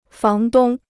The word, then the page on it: 房东: fáng dōng